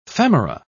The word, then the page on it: femora: British English